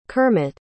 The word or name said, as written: kermit